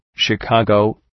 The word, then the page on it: Chicago: ʃı’kɒgəʋ